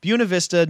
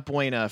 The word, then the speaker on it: Buena Vista: So, yeah, Buena Vista, not Buena, for those of you who are just joining us.